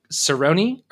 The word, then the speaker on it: Caroni: and we were talking about Caroni, but apparently it's pronounced differently there, like Cerrone or something. – What? – Like that's how they pronounce it locally.